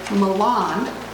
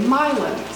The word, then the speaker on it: Milan: And I have recently learned, this will be good for Harland, that they don't say Milan. – Milan. – They say Milan. So when you hear me pronouncing this town in Minnesota, which is close to where Harland grew up, you will hear me pronouncing it the correct way.